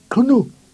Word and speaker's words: gnou: Tu entends de gnou, c'est un mot Bushman qu'il faut prononcer gnou avec un clic.